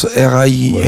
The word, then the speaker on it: Rijn: En van, V-A-N, et R-I-J-N, qui se prononce R-I-N.